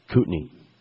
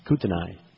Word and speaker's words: Kootenai: By the way, it's pronounced Kootenai. – What did I say? – Kootenai. – Well, that's because it's in Idaho and you're a Montana boy.